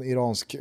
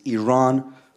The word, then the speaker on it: Iran: Our country is named Iran, not Iran. Please once and for all, let's get this clear.